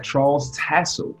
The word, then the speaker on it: Charles Tassell: Today we have Charles Tassell.